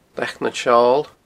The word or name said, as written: Leac nan Seol